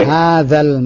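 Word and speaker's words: Hadza (هذا): Hajal mu'jamu. – Hanya panjang. Hadzal mu'jamu. – Hajal mu'jamu bimi'ati. – Zalnya lebih perhalus lagi Pak An. Pengucapan zal. Hadza.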